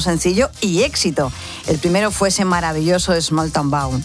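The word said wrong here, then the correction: Smalltown Boy: Small Town Bow. Hoy voy, ahí se lo digo bien. – A ver, ¿cómo era? – Small Town Boy.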